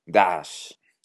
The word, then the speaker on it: Daesh: Bueno, pues en 2013, el activista sirio Khaled al-Hajj Saleh creó el acrónimo Daesh, que en árabe se pronuncia Daesh.